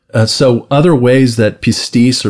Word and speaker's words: pistis: Uh, I'll spell it first P I S T I S, pistis, or pistis, or pisteuo, and it's the word faith.